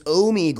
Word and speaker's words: Omegle: Pronunciation is not pronunciation. It's Omegle.